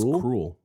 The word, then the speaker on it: Kroll: Scroll or somebody told me his last name was pronounced Cruel, which I think is appropriate.